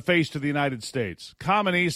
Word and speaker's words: Khamenei: But Ayatollah Ali Khamenei, I don't know. How do you say the guy's name? Khamenei, there you go.